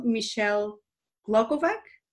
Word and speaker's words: Michelle Glogovac: Please welcome Michelle Glogovac.